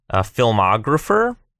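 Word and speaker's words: pfilmographer: F-E-I-L, and Twitter with another silent P, filmographer. So it's P-F-I-L-M-O-G-R-A-P-H-E-R.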